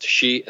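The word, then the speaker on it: shi (四): Four is an unlucky number in Japanese numerology because it's pronounced shi, and that's the same as the pronunciation for death.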